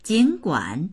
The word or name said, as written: jin3guan3